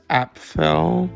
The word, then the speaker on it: Apfel: and also famous for the documentary Iris about how do you say her name? Because she's still with us. She's 100. And she's a fashion... Iris Eiffel? Let me see. Documentary. Let me see. Yeah, Iris Apfel.